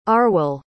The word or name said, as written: Arwel